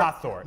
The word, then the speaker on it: Hathor: I start Hathor, H-A-T-H-O-R.